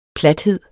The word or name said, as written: PLATHEDEN